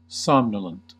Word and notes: somnolent: American English

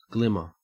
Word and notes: glimmer: Australian English

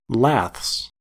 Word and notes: laths: American English

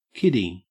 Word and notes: kiddie: Australian English

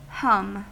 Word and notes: hum: American English

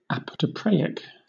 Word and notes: apotropaic: British English

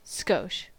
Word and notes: skosh: American English

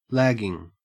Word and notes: lagging: Australian English

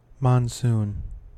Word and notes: monsoon: American English

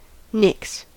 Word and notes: nix: American English

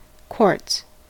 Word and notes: quartz: American English

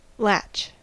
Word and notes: latch: American English

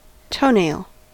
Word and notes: toenail: American English

